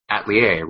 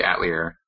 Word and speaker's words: Atelier: The in-game dub changes the pronunciation of it, and it's a French word, so it probably should be Atelier, right? But in this one, they say Atelier.